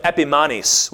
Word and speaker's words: Epimanes: Behind his back, they called him Epimanes, which rhymes with Epiphanes, but Epimanes means madman, which is exactly what he was.